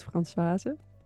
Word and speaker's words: Françoise: So with Dutch pronunciation, it's Françoise.